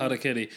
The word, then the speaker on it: Harakiri: So I've been revisiting some of my all-time favourite films recently, and one such film is Harakiri. Did I say that right? – Harakiri. Yeah, amazing.